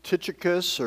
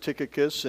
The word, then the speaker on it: Tychicus: It begins with one of those strange names, Tychicus, or some people pronounce it Tychicus, and, and I will probably go back and forth as I pronounce it throughout this morning.